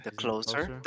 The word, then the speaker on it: The Closer: So, I don't know if anybody knows, but this month, Dave Chappelle dropped a new special, and it was called The Closure. Yeah. – The closer. The closer. No, the closer.